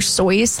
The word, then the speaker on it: Seuss: Dr. Seuss is actually supposed to be pronounced like Dr. Sois, like moisture. – Really? – Or moist voice, which is now going to be stuck in your brain.